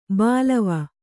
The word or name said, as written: ಬಲ್ಲವ